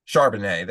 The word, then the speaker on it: Charbonnet: It's like Charbonnet or something like that. – Charbonnet.